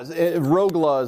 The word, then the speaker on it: Rogla: Yeah, Rogla is how I pronounce it. It's almost like a GLA at the end of it for us North Americans.